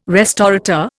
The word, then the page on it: restaurateur: Indian